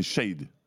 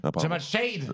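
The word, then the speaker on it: Shead: C'est-à-dire après, on est dans la prononciation, c'est Shade. – Jamal Shade. – Ouais. Jamal Shade. – Mais moi aussi, je l'appelais Shade et tout le monde m'a repris.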